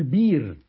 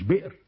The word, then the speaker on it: بئر: أبو جعفر المدني، سواء فاء كلمة عين كلمة لام كلمة مش هينطقوا، فيقول ايه مؤمنون، ويقول بير مش بئر، ذيب مش ذئب، يقول كاس مش كأس، تمام؟